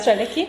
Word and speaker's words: Strelecki: Und ich glaube, das hatte in einem Interview, ich weiß gar nicht, wie man seinen Nachnamen ausspricht, John Strelecki. – Strelecki. Ja. Wir haben heute den Tag der unaussprechlichen Namen.